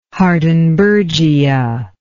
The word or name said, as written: hardenbergia